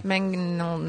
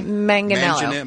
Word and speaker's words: Manganiello: Manganiello. – I don't know how to say his name. I never have. Manganiello? – Manganiello.